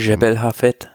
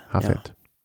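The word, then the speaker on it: Jebel Hafeet: wie spricht man das, Jebel Hafet hochkommen. Jebel Hafet. – Hafet.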